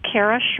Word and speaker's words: Kaira: So my name is Kara Sherman, and it's spelled K-A-I-R-A, and then last name S-H-E-R-M-A-N.